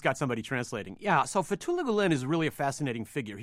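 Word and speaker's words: Fethullah Gülen: Fethullah Gulen? – Fethullah Gulen.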